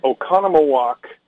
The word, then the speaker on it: Oconomowoc: It took me like 2 days just to pronounce the correct name of the city, but it is Oconomowoc, Wisconsin.